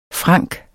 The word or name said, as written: franc